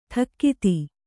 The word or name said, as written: ಠಕ್ಕಿತಿ